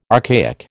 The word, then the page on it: archaic: American English